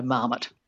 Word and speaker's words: marmot: So it's had a lot of time, to sort of be squished into an English model of stress, which would be the stress on the first syllable, so marmot.